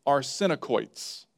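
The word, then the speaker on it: arsenokoites: It's arsenicoits. Arsenicoits. Let me spell that for you. A-R-S-E-N.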